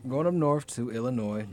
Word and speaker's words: Illinois: Anybody know how to say, this is a test, how do you pronounce the state that Chicago's in? – Illinois? – Thank you. Right. Thank you. – Illinois. – If I hear one more S.